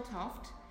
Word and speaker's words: Toft: Kim Michelle Toft, K-I-M, mm-hmm. Michelle, M-I-C-H-E-L-L-E, Toft, mm-hmm. T-O-F for Fred, T, Toft.